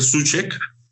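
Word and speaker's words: Souček: Se debe pronunciar Suchek.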